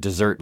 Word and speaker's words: desert: Confusingly, even though desert in moral desert is spelled D-E-S-E-R-T, it's not desert, like the dry, sandy place where camels roam around, nor is it dessert like ice cream, although that's how it's pronounced.